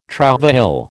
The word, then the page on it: trouvaille: American English